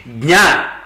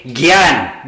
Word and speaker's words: jna: It's we have to pronounce properly jna. We have to say jna. And in North India, that jna is not there. They say gyan.